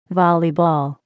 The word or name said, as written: volleyball